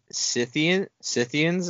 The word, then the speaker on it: Scythians: Scythians is how you said it? – Yeah, I tried, yeah. – Yeah, that's how it's pronounced.